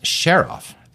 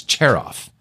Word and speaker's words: Cherof: People at work here don't realize that his last name is pronounced Cherof. They think it's Cherof. – Right. – And that's totally wrong.